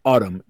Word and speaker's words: Atum: Okay, Atum, a rock opera in three acts, and yes, this this album is called Atum. It's pronounced like the season.